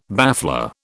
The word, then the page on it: baffler: British English